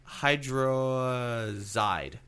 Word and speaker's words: hydrazide: P-A-S. Okay. – PAC. – Yes. The next one was isonicotinic. – Perfect. – Acid hydrazide.